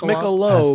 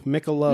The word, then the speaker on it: Michelob: Is it pronounced, do you do the hard B? – Michelob. – Is there a hard B in Michelob? – It's Michelob. – I thought it's Michelob. – Like Mica earlobe. Michelob.